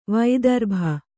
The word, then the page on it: ವೈದರ್ಭಿ: Kannada